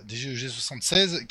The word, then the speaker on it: DGEG76: donc c'est degne 76 J'ai toujours du mal à le prononcer, je suis désolé pour ton pseudo. DGEG. E voilà, DGEG76 qui nous avait fait ça.